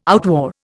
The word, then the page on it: outwore: Indian